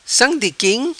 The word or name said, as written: Sam Di Quim